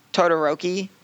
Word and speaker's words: Todoroki: and we're gonna get a little more Iida development, which his fight with um, Todoroki, what to, to yeah, that was Ice it.